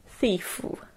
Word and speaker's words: thief: 然后发出了气音 F，不要念成 thief OK，这样子感觉好像后面多了一个 U 的声音，不要这样念 thief。